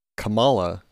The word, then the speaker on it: Kamala: It is from Izzy, and she writes, Kamala rhymes with Mamala.